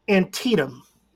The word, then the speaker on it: Antietam: I believe it's Antietam.